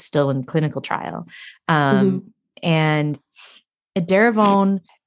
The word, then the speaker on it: Edaravone: But you mentioned a couple of times the infusions, and I wanted to talk about that because Jessie chose to take a drug called Edaravone. I'm pronouncing that right? Edaravone? – Yes. Yes.